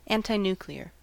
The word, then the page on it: antinuclear: American English